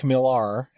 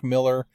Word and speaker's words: Millar: Uh, before I get into the comic at hand, uh, this was written by uh, Mark Millar, and it might be just Mark Miller. I've heard it pronounced both ways.